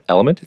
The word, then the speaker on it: LMNT: If supplementing your electrolytes seems right for you, I highly suggest Element. It's spelled L M N T.